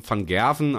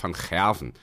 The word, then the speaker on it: van Gerwen: und die meisten Leute sagen aber alle van Gerwen, aber korrekt wäre eigentlich van Gerwen. So ist richtig, ne, René, erstmal.